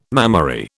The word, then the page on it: mammary: British English